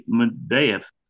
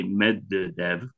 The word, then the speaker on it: Medvedev: That's Dmitry Medvedev. As the Americans say, Medvedev, which they can't pronounce from Russian, Medvedev said the four horsemen of the apocalypse have been released.